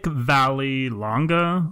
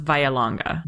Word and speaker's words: Vallelonga: So and then Nick Vallelonga, let's say. I think it's pronounced Vialonga. We'll we'll go with that.